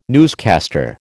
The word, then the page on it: newscaster: American English